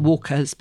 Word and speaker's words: warqa: I thought it was just Walker, W-A-L-K-E-R, as in White Walkers. But it's not. It's spelt W-A-R-Q-A, Walker. And we say brick pastry, but it's very often spelt B-R-I-K as well.